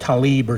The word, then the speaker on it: Tlaib: That's why that Islamic traitor Tlaib, or whatever her name is, it's spelled T-L-A-I-B, Tlaib, but they say it differently. They say like Talib or something. She ought to just correct the spelling. That way we can pronounce it T-I-L-A-B, you know.